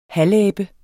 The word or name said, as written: halvabe